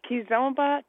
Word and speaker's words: Kizomba: Kizomba, K-I-Z-O-M-B-A.